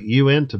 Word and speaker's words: Uinta: It's actually UINTA. Unta. – Uinta. – Uinta. – Nah, hell, I don't know how you say it. – Uinta. – It was easier when I thought it was Unita.